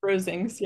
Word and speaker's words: Rosings: Rosings? – Rosings, yeah.